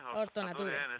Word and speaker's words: Hortonature: Bueno, lo primero, ¿lo he pronunciado bien? ¿Lo dicen ustedes Hortonature, Hortonature? ¿Cómo lo pronuncian? – Hortonature. En español, sí.